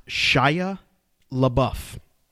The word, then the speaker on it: Shia LaBeouf: We got an email here from Adam who says, look, guys, it's pronounced Shia LaBeouf.